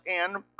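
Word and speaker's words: en (ἐν): The word in Greek, en, which is pronounced en, close to our word in, which is what it means.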